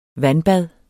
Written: vandbad

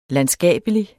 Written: landskabelig

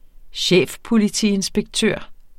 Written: chefpolitiinspektør

